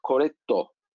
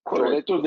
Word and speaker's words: Corretto: nasıl tercih ederiz? Corretto. – Corretto diye, diyebiliriz sanırım. Türkçesi diye telaffuz ediliyor. İtalyanca bir e, ifade zannediyorum. Bu biliyorsunuz Amazon önemli.